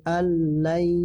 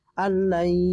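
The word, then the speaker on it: alla (أن لن): Ayahsabu alla, bacanya alla jangan anla ya. Alla langsung ditasdid, itu namanya ya idgham bilagun bilagunna, karena lam situ termasuk huruf bilagunna ya.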